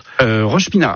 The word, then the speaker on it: Rochepinard: Alors Rochepinard, c'est ça, c'est bien comme ça qu'on prononce. – Rochepinard.